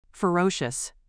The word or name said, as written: ferocious